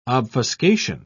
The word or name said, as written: obfuscation